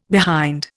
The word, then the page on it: behind: Indian